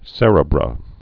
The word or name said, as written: cerebra